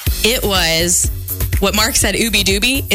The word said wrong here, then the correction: Ubby Dubby: It's actually Ubby Dubby. – Ubby Dubby, excuse me.